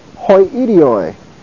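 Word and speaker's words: hoi idioi (οἱ ἴδιοι): It is a proclitic, so it is pronounced hoi idioi.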